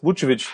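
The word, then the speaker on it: Vukčević: Boris Vucevic. – Mit dem richtig spricht man ihn aus, wie ich es gerade gesagt habe übrigens.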